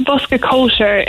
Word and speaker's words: Bosca Cóisir: And did I pronounce it correctly? – Yeah, it's Bosca Cóisir.